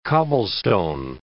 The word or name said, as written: cobblestone